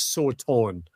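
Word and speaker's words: Sauton (שטן): So the Hebrew word Satan, and the proper pronunciation for that Hebrew word, by the way, is Sauton. But I'm not going to translate or pronounce it as Sauton in my time with you, because we all know the pronunciation Satan.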